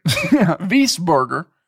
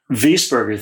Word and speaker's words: Wiesberger: Yeah, Bernd Wiesberger. – Wiesberger. – Wiesberger, thank you for the pronunciation there.